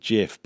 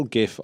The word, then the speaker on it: GIF: Yeah, a lot of people pronounce it GIF, but I've always pronounced it GIF.